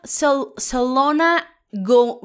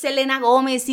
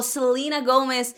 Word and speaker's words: Selena Gómez: Y oye, bien mal que pronunció los nombres. Selena, Selona go, O sea, cállate. La conoces de los hechiceros de Waverly Place. ¿Cómo no vas a saber que es Selena Gómez, hijo? Selena Gómez.